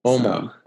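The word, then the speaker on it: Åmål: It's spelled, you know, AMAL, but both A's have a little circles over them, you know, Swedish, so it comes out like an OU sound.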